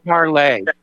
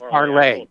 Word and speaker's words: Parler: I shared it Parlay. – Big pardon? – I think it's pronounced Parlay. – Yeah, probably. Yeah. That's kind of like a French name.